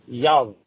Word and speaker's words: jävla: and when you pronounce it in Swedish, it is jävla, J-A with umlaut, V-L-A.